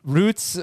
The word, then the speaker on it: routes: How do you pronounce R-O-U-T-E-S? – It depends what word you're talking about. – Roots or rhymes with shouts? – Yeah, you run a route, and you drive on Route 66.